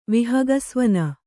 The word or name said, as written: ವಿಹಗಸ್ವನ